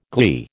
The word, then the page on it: gley: American English